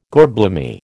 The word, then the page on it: gorblimey: American English